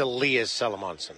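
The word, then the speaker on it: Elias Salomonsson: I wanted to say Elias Salomonson. – It's Elias Salomonson.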